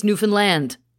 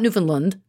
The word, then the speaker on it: Newfoundland: It's Newfoundland. That's how we pronounce that. It's not Newfoundland.